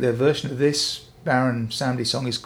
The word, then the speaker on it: Baron Samedi: Yeah, Baron Samedi. French for Saturday.